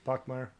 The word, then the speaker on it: Bachmeier: All right, so as quarterback, Hank Bachmeier, I think is how you say his name. – Yes, Bachmeier.